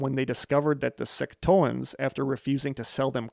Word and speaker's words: Secotan: The hostility began soon after their arrival when the English accused the Secotans, I don't know how to pronounce it, S-E-C-O-T-A-N, Secotans? – That sounds right.